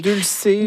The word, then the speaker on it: dulse: Dulce ou la Dulce, je ne sais pas comment le prononcer. – Dulce, en tout cas, D-U-L-S-E.